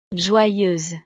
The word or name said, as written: joyeuse